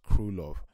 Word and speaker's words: Krylov: And I think Krylov, that's actually pronounced Krylov.